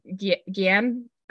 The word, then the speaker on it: Guillaume: His name is Guillaume. – Guillaume, but we're terrible at pronouncing it.